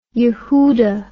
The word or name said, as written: Yehuda